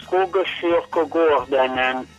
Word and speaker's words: Skogskyrkogården: Sono tre parole, Skogskyrkogården, vuol dire il cimitero nel bosco.